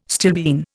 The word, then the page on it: stilbene: Indian